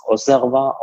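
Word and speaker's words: Auxerrois: Die Stadt wird ja mit X ausgesprochen, Auxerre. Der Wein, meines Wissens nach, wird er als Osserwa ausgesprochen, also X wie S.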